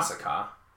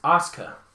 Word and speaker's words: Asuka: Yeah, the last one was from Dallas, The one that had Asuka against Eva Marie. – Asuka. – Yes. – Not Asuka. – Asuka. – It's Asuka. – Asuka. – Yeah. You're putting an extra vowel in there. It's just Asuka.